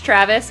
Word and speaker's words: Traves: Uh, it's Smash Traves, so S M A S H, and then my last name is spelled T R A V E S, like Trace.